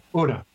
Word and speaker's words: Una: Una. U-N-A. – That means one in Spanish. – I know. It's the first child. – And the next is going to be dos. – Tony. – Una, dos, tres, cuatro.